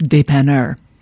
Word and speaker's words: dépanneur: Again, that's pronounced dépanneur.